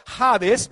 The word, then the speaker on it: Hades: Y la palabra Hades viene del griego, que se pronuncia casi igual que en el español, Hades,